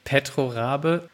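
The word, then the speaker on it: Petro Rabigh: Die Anlage, um die es hier geht, die gehört Petro Rabai, und das spricht man, glaube ich, ganz anders aus. Hast du das, glaube ich, mal recherchiert und versuchst? – Petro Rabe.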